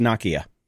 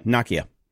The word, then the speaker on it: Nokia: And by the way, it's pronounced Nokia. – Is it really? – Yep, Nokia.